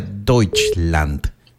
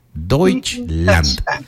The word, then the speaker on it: Deutschland: Pero si sí le interesa, se pronuncia Deutschland. Deutschland. Eso.